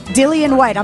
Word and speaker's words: Dillion Whyte: Dillion Whyte.